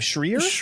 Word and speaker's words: Schreier: Schreier.